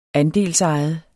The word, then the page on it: andelsejet: -ˌɑjəð; -ˌɑjˀəð